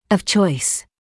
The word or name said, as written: of choice